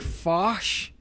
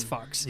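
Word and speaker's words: Fuch: It might have been pronounced like Fosh or something, but it's Fox. Yeah. His name is Fred Fox.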